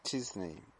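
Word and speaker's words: t-SNE: One technique popular with the project's biologists is the data visualization tool called TSNE, pronounced TSNE, which groups the cells according to similarities between the thousands of genes' expression scores.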